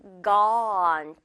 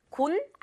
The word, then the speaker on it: gone: 이거는 gone. 또 역시 어 발음. 맛있는 우리 떡 받아먹는 발음 있죠? 입 크게 벌리는 발음이에요. gone. 예문 간단합니다. 그 사람들 갔어? Are they gone?